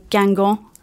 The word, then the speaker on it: Guingamp: They are knocked out of the Coupe de la Ligue by Guingamp. Have I said that right, everyone? – Yeah. – Yes.